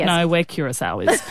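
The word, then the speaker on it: Curaçao: Curacao. – Curacao.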